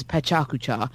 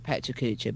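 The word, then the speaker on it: Pecha Kucha: she pronounces it the proper Russian way, which is Pechakucha, but I pronounce it Pechakucha because I'm English and rubbish at these things.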